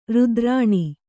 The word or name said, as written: ರಥರೇಣು